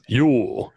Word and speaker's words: Jul: Yule, yes. J-U-L, pronounced Yule. – And then we have bastardized it to Yule. – To Y-U-L-E.